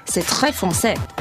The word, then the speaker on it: C'est très français: C'est très français.